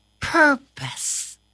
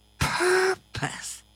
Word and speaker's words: purpose: It is pronounced purpose. – Purpose? – Yes, purpose. – Like in the ocean, the animal of the ocean? – No, no, no, Corona, that's a porpoise. Our word today is purpose.